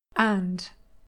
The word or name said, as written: and